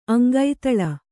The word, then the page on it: ಅಂಗೈತಳ: Kannada